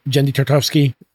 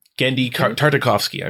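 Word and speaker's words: Genndy Tartakovsky: Is it Jendy Tartakovsky? – Yes. Mm-hmm. Jendy. Yeah. – Gendy Tartakovsky, I believe.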